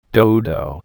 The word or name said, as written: dodo